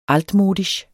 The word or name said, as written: altmodisch